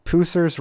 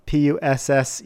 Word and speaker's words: Pusser's: So to make it the true way, you need to use, I think it's pronounced Pusser's Rum, Pusser's Rum,